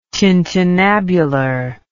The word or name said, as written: tintinnabular